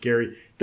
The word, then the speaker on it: Gerry: The term gerrymandering fell out of favor and it came back and people didn't really know how to say Elbridge Gerry. The G is a hard thing.